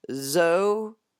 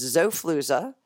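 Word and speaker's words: Xofluza: Yeah, but it's spelled with an X, so it's Zofluza. XO, then Fluza, just how Fluza sounds with a U, not two O's.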